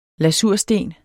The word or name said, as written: lasursten